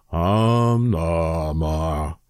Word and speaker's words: Om Namah: And you just go out and you sit there and you go, Om Namah. Om Namah. So that's kind of spelled like O-M-N-A-M-A-H. Om Namah.